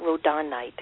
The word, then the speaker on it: rhodonite: I always say that wrong, rhodonite, really. It's spelled R-H-O-D-O-N-I-T-E.